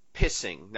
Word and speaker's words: Pising: Now, it's spelled P-I-S-I-N-G, and again, in the DVD credits, he's Esquire. So again, you get a law degree when you go on the show. But he's Steve, it's not pising, yeah. It's not whatever any other pronunciation you can think of, it's pissing.